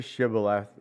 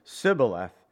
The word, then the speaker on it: Shibboleth: You see, the closest the Ephraimites could get to Shibboleth was Sibboleth, and their pronunciation gave them away.